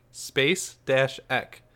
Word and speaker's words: Spacek: So she says, you guys, Sissy Spacek, Space-Ek, I love you, but you're killing me. Killing is in all capitals. So, Jen, we said it right. George, say Sissy Spacek. – Sissy Spekek. – No, that's wrong.